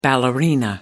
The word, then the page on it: ballerina: American English